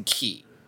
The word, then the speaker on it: chi: In the written dialogue, it's written as the Greek letter chi, which they're pronouncing key.